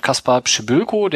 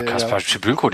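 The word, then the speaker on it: Kacper Przybyłko: Ich applaudiere dir gerade für das korrekte Aussprechen dieses Namens. – Kaspar Pschibylko. Das hast du bei Rainer geübt, also Rainer Wolf? – Na, Kaspar Pschibylko, der hat doch letztes Jahr mit Bielefeld schon 2 Tore gegeben.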